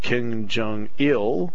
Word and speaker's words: Kim Jong-il: Yet, Kim Jong-un, the son of Kim Jong-il, emphasis on the last syllable, claims and has told the people of North Korea that he once had 18 hole-in-ones in an 18-hole round.